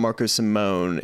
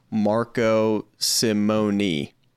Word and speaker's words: Marco Simone: It's not Marco Simone. It's actually three syllables. It's Marco Simone.